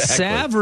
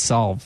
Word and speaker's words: salve: Is it salve or is it solve? – Salve. – Sure. – Yes. – I think it's solve. Salve. There's an L in there. – The L is silent.